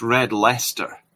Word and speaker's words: Red Leicester: Well, I should say it's pronounced Red Leicester.